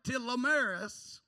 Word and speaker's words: telomeres: Researchers at Brigham Young University, who studied the DNA of nearly 6,000 adults, found that telomeres, and I looked it up to make sure I'm saying that right, because it ain't spelt that way,